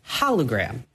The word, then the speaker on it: hologram: It's pronounced hologram, not holy, not solo, not polo, hologram.